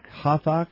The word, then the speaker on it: Hathach: Then Esther called for, how do you pronounce that? H-A-T-H-A-C-H? – Yeah, Hathach. – Hathach? – Sure.